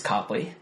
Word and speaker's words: Copley: I want you to know, Joe thinks your last name is pronounced Copley. – Joe's wrong. – Your roommate Joe. – My roommate Joe. – Copley. – Honestly. When people say Copley, I don't correct them because it doesn't bother me at all. – Sure. – It's Copley, though. It's C-O-P-L-E-Y, not C-O-P-E-L-Y.